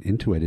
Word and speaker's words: Intuit: what Intuit is doing.